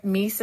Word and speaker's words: Mies: And it's spelled M-I-E-S. Is that right? – Yes. So I'm going to blow your mind here. Mies is actually not my full name.